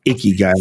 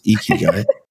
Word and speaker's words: Ikigai: No, you actually said it right. When people say Ikigai, I'm going to punch them in the face. It's Ikigai.